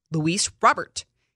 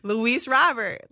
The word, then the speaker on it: Luis Robert: Like, he's not, in, like, it's not Robert. Robert. And you just don't, you leave out the T because you don't really, well, you just wouldn't pronounce it like that.